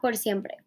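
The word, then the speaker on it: X 100PRE: Okay, let's talk about Por Siempre. It's spelled X100PRE, which is Por Siempre, which means like forever.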